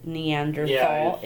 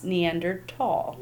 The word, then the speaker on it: Neanderthal: Even though it is spelled Neanderthal, it's pronounced Neanderthal. – Neanderthal, okay.